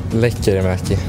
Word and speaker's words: Lekkerimäki: Now, if you look at his name, it looks like it would be pronounced, we'll say Jonathan. I know we would say Jonathan, but Jonathan Lekkerimäki. But let's let him tell us how to say it. – Lekkerimäki. It's tough, but...